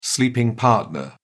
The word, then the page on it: sleeping partner: British English